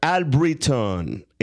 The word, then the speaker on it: Allbritton: And welcome, Amber Allbritton.